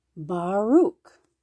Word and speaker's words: Baruch: Let's look at his Oh, the poor guy's name is Baruch. There you go. We're saying it right.